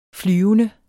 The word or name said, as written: flyvende